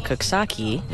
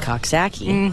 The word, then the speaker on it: Coxsackie: And then I now live in Coxsackie, nice. – Which my family insists on pronouncing Coxsackie.